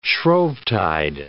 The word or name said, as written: Shrovetide